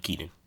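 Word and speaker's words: Keiynan: Wasn't the first name? – Keenan. – Oh, it's just Keenan? Okay, the way it's spelled is weird.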